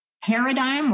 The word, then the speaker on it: paradigm: So it's such a completely new paradigm, a word I hate because there's that silent G, but it's just a new way of looking at childhood that feels instinctual because everybody's doing it, but it's not.